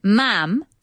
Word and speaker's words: ma'am: It says the Queen is addressed as Your Majesty, and then ma'am, to rhyme with ham, not farm. And I called her mom. That's wrong.